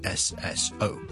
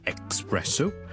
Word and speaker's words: espresso: 第二个字咧都系 es 开头嘅，就系 espresso， espresso， espresso， espresso，即系意式嘅特浓咖啡。我哋呢两个字嘅 es 咧，我哋都唔好将佢读成 x，唔好 espresso，唔好 especially， but espresso， e 或者 e 都得。接加个 s，中间唔好加咗个 k 音，因为 k 音咧，我哋 e x，我哋先会有 k 音嘅。